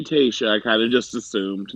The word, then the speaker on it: Tayshia: And if I'm not mistaken, and I don't know if I'm pronouncing it correctly, T-A-Y-S-H-I-A. We'll go with Tayshia.